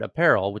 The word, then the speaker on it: APPRL: And Cal, I had not seen the headline that Klarna had acquired Apparel, which is spelled APPRL, as you said.